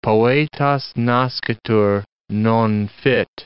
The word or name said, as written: poeta nascitur, non fit